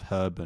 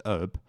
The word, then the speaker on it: herb: Was about the difference between the pronunciation of herb and how you Americans say it, which is herb. In some parts of America, of course, where you just don't pronounce the H for some reason, and you really overstress the B and all sorts of weird stuff like that.